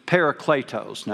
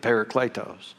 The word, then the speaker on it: parakletos: Now, I know there's other ways to pronounce that. You don't know all that. And that's the way they taught us to say it in seminary. So that's the way I'm going to say it, parakletos.